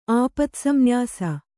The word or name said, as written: ಆಪತ್ಸಂನ್ಯಾಸ